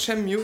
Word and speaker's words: Shenmue: Ich war bei Sega und es wird echt Shenmue.